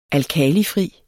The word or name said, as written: alkalifri